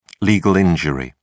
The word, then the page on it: legal injury: British English